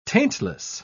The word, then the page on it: taintless: British English